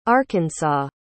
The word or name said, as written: arkansas